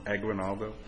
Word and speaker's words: Aguinaldo: Aguinaldo.